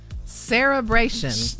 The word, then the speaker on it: cerebration: Cerebration. – Yeah, you said it correctly. Cerebration. – Never heard of it. – It's not an L. It's not a celebration.